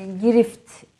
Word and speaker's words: girift: E, grif, e, girift doğru telaffuz ettim inşallah evet.